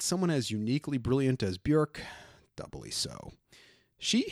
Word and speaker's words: Björk: You may call her Björk, but it's actually pronounced Björk. It rhymes with work.